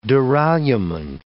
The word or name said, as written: duralumin